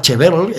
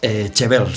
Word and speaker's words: Chevelle: Chevel se llama, Chevelle se escribe, ¿vale?